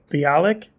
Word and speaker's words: Bialik: So one was I was on a date with Mayim Bialik. Bialik. How do you pronounce Blossom's name? Uh, that sounds about right.